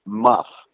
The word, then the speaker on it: Mough: Her last name was spelled M-O-U-G-H, pronounced Muff.